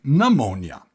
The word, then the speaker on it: pneumonia: This is another word I think you'll recognize in English, except in English we don't pronounce the P sound. Instead, for example, we say pneumonia. The P is silent.